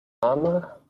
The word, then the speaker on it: Rama: Is Rama with a long A? I can't remember. Ramo or Ramo? Rama? It's a long A, right? – Ramo? This is a long A, not yeah. Ram. – Ram.